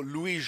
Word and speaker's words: Louis: con l'accento francese, proprio Louis Jordan, proprio perché venisse evitato che venisse pronunciato Lewis, scritto Lewis. Evidentemente era una cosa che gli dava particolarmente fastidio, quindi per evitare errori di pronuncia lo faceva pronunciare in francese.